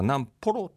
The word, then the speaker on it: 南幌: ホロで読み方は南ポロって言いますね、はい、なんか可愛いですね、南ポロってね、勝手にですね、南ポロのことをこうずっと追っていく人をですね、フォロワーじゃなくてね、南ポロ